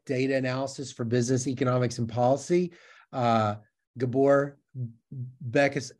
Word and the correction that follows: Békés: I said it wrong. How do I say it? It's Gabor Bekes, but that was close enough. Gabor Bekes with with Gabor Kezdi uh,